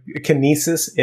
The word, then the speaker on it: Kinesis: How do you pronounce this? – It's the Kinesis Advantage 2.